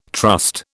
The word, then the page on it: trust: British English